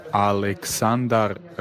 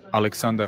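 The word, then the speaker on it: Aleksandar: It's like the bank Santander. – Alexander. – So it's spelled like Alexander, like S-A-N-D-A-R. And when he slows it down, he says it that way. But when he speeds it up, it's more like Alexander.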